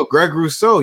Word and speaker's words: Rousseau: So I have them taking Greg Rousseau, is that how you pronounce it? – Am I correct? Yeah. – Here we go. Greg Rousseau, you know, they're going to need some help back there.